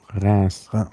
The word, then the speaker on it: Reims: Und dann so nach Norden bis nach Reims, glaube ich. Ist das richtig ausgesprochen? – Reims.